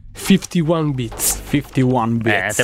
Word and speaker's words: 51 Beats: 51 bits. – Te pareva, me ne vado, io me ne vado, me ne andrei. – Giuro di aver sempre detto 51 bits anch'io, anzi anche male a volte, quindi...